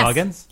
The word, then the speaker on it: Goggins: Goggins, yes. – Right? – Yes.